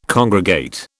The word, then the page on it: congregate: British English